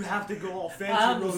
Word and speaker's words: Rosé: Rosé. They have the accent on the E.